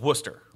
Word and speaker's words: Worcester: Well, in Boston, there's a place near Boston, and it's Worcestershire, and they call it Worcester.